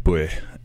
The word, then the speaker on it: buaidh: the word is spelled B-U-A-I-D-H, buih, and it means not just victory, but also impact or mm-hmm.